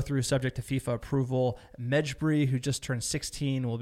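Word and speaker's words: Mejbri: The next piece of news, Hannibal Medjbri. I can't pronounce that, sir. You want to give it a shot? Hannibal Medjbri? That sounds pretty good, sir.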